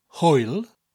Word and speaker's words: hwyl: These include hwyl, spelled H-W-Y-L, hwyl fawr, means a big goodbye, because fawr means big in Welsh.